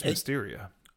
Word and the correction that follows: Themyscira: Did I butcher it? – Themyscira.